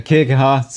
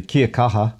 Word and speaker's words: kia kaha: Yeah, kia kaha. Stay strong. I've got to say it properly. Kia kaha.